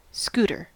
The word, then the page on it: scooter: American English